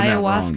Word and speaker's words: ayahuasca: No, I'm saying ayahuasca. – That wrong. Ayahuasca, is that how you say it? – I mean, is it the city? – Yes. Okay, No. It's a hallucinogenic drug. – Yeah, it's ayahuasca.